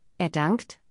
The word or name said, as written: er dankt